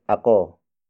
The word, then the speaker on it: ako: The next word is ako. Ako. Ako. Ako, spelled as A-K-O.